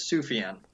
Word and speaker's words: Sufjan: Our friend Matt asked me how to pronounce his name, and it is Sufjan.